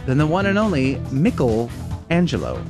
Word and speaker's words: Michelangelo: It's Michelangelo.